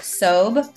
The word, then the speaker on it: Sobh: And then my Instagram is Brooke Sobh.